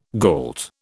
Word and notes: gault: British English

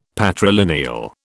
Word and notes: patrilineal: British English

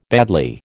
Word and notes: badly: American English

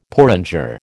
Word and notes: porringer: American English

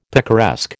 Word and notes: picaresque: American English

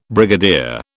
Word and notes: brigadier: British English

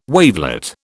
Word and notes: wavelet: British English

